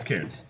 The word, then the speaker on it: Cairns: It's Cairns.